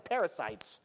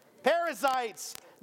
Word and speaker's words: Perizzites: She's like, it's not the Perizzites. Perizzites, the Hivites, and the Jebusites.